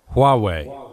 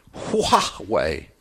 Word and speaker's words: Huawei: Huawei. – Huawei.